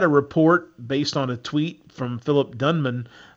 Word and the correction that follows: Dunham: or I think I mispronounced his name. Is it Dunham? Am I saying that right? – Yeah.